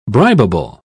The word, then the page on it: bribable: British English